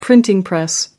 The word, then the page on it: printing press: American English